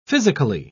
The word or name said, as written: physically